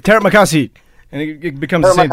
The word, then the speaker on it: Terima kasih: Terima kasih. – Terima kasih.